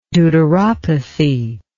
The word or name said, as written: deuteropathy